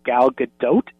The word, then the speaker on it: Gadot: Yeah, and I hate to be the pronunciation police, but do you know that her name's actually pronounced Gal Gadot? You pronounce the T.